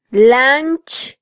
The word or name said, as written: lanch